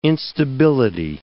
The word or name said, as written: instability